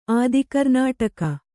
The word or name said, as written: ಆದಿಕರ್ನಾಟಕ